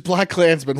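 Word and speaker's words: BlacKkKlansman: It's just Black Klansman, one word.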